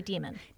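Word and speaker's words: daemon: Demon or Damon? – I listened, and they did say Demon.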